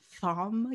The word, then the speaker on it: Thom: Hearing Damage by, is that pronounced Tom? Tom York? – Yeah. – Thom York.